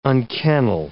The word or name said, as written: unkennel